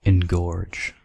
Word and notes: engorge: American English

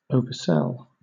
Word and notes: oversell: British English

